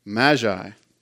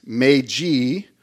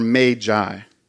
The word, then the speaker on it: Magi: Now, if you're like me, you grew up hearing this word pronounced Magi, but the correct pronunciation of this word is Magi or Magi.